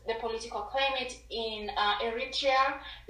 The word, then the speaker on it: Eritrea: No, you say it right. It's Eritrea, so that's what he says. It's correct.